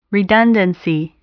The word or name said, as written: redundancy